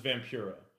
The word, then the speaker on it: Vampiro: So he mispronounces Vampiro as Vampiro. Says it three times.